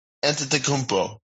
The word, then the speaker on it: Antetokounmpo: Antetokounmpo, right? Is that how you say it? – Antetokounmpo.